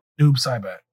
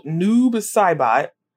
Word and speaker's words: Noob Saibot: Noob Saibot. – Oh, Noob Saibot.